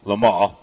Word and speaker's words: Le Mans: And I've always said that Le Mans, but I think the pronunciation is supposed to be Le Mans.